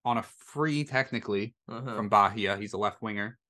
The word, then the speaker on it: Bahia: They sold him to Bahia, which probably definitely not how you pronounce it, because they're in Brazil, and I don't think Brazilians do a on their H. – Bahia.